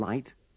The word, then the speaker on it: light: In these sentences, don't pronounce the GH in light and lighting.